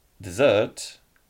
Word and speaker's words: dessert: Można było przypadkiem powiedzieć o pustyni zamiast o deserze, tak? Czyli deser było jak? Dessert, a pustynia desert.